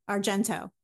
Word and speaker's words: Argento: So I saw that you like Dario Argentino, or no, Argento. I'm saying that wrong.